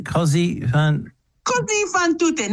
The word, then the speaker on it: Così fan tutte: Così Fan Tutte.